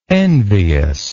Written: enviousness

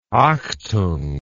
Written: achtung

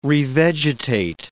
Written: revegetate

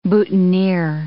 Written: boutonniere

